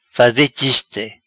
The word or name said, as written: Faze chiste